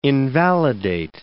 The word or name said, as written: invalidate